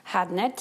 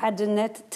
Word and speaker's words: Hadnet: Hadnet Tesfai. Äh, richtig ausgesprochen übrigens Hadnet Tesfai.